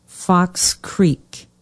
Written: Fox Creek

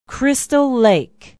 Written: Crystal Lake

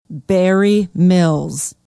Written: barre mills

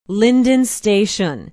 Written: lyndon station

